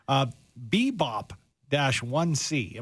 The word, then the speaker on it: BEBOP-1c: Bebop-1c. Am I saying that correctly? That's another recently discovered gas giant of a planet. What is this? – You're saying it correctly.